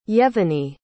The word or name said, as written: yevheniy